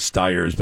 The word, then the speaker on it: Stiers: David Ogden Steers, I think it's Steers, maybe other people pronounce it Stiers, but anyway.